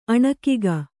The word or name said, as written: ಅಣಕಿಗ